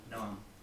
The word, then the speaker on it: Noam: N-O-A-M. Noam. – N-O-A-M.